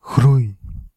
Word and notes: développement: France; [de.vlɔp.mɑ̃]

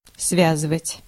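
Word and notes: связывать: /ˈsvʲæzɨvətʲ/; Russian

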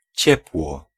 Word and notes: chaleur: [ʃa.lœʁ]; France

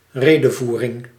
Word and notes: discours: /dis.kuʁ/; France

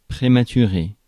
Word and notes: prématuré: [pʁe.ma.ty.ʁe]; France

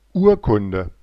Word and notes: Urkunde: /ˈuːɐ̯kʊndə/

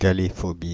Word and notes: galéphobie: France (Île-de-France); /ga.le.fɔ.bi/

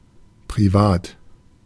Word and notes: privé: France; [pʁi.ve]

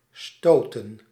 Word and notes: stoßen: /ˈʃtoːsən/

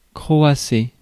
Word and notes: croasser: [kʁɔ.a.se]

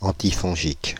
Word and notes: antifongique: French

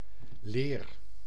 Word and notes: leer: /leːr/

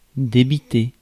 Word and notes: share: American English; /ʃɛɚ/

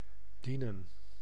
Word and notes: bedienen: [bə.diːnəⁿ]; /bəˈdinə(n)/

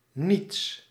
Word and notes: rien: [ʁi.ɛ̃]; France (Paris)

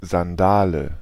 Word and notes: Sandale: /zandaːlə/

